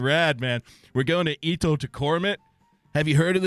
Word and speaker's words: Ittoqqortoormiit: Itokotomit. Okay, we'll let the guy say it. – Itokotomit? Itokotomit.